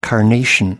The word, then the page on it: carnation: American English